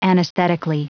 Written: anesthetically

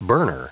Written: burner

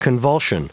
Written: convulsion

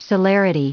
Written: celerity

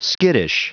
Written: skittish

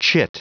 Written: chit

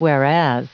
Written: whereas

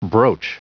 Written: broach

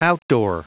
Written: outdoor

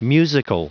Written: musical

